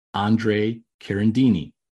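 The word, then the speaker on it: Andrea Carandini: In episode four, I mispronounce an Italian archaeologist's name, Andre Carandini. This is the correct pronunciation, and he is the author of the research and book, Rome, Day One.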